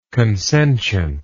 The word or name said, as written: consentient